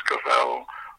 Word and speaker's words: Scovell: I picked up the Daily Sketch, and it said, Brian Scovell rhymes with shovel.